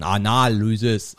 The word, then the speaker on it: Analysis: Wenn Mathilda mir erzählt, was sie jetzt in E macht, Analysis wird das übrigens richtig ausgesprochen. Analysis.